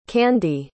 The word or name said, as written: Kandy